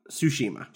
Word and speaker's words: Tsushima: Tsushima? – Tsushima.